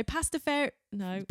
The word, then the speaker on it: Pastafarianism: Is that right? How do you say that? Pastafarianism, that's right. – It. Pastafarianism. I keep getting that wrong. I tried to practice that and I still get it wrong.